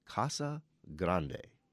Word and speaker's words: Casa Grande: We don't have the words Casa Grande in English dictionary, so they need to be pronounced as the Spanish words that they are. The correct pronunciation of Casa Grande and the town named this is Casa Grande.